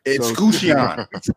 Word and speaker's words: Escutcheon: This, you know, I don't want to talk about it because I don't know how to pronounce the brewery. – Escutcheon. Escutcheon.